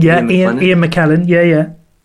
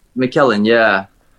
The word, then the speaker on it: McKellen: I think Ian McLennan, is that how you say his name? – Ian McKellen. – McKellen, said this in a TV show once.